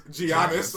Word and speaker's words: Giannis: Giannis.